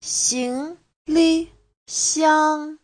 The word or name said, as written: xing2li5xiang1